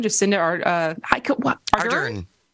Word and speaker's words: Jacinda Ardern: New Zealand PM, Jacinda Ardern.